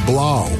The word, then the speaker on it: Blau: David Blau rhymes with cow. I love it, man. – And plow, and now, and bow.